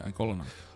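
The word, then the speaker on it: Y'golonac: No, you're thinking of Igolonok. The Y is pronounced like an I. – Is it I? – Yeah. – Igolonok? – Yeah, Igolonok.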